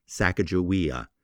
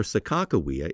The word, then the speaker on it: Sacajawea: How do you say her name? Linguists disagree. The reason people call her Sacajawea is the fact that Sacajawea is the Hidatsa name,